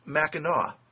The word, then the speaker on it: Mackinaw: And then there's another place that's spelled almost like Mackinac, but it's pronounced Mackinaw.